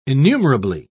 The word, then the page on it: innumerably: British English